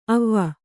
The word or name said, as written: ಅವಿ